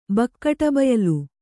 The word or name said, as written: ಬಕ್ಕಟಬಯಲು